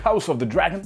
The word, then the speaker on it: House of the Dragon: qu'on a pu voir aussi dans la série House of the Dragon. Il faut toujours prononcer le nom de la série comme ça.